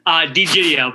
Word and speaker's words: D'Egidio: DiEgidio, but that was a common mispronunciation.